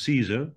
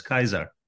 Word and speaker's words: Caesar: Everybody started to use Caesar almost as a title. And of course, you know, the Romans didn't pronounce it as Caesar, but as Kaiser, which is the German Kaiser.